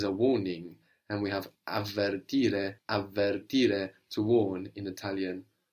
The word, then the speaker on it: avvertire: To warn in Italian is avvertire, two V's, avvertire.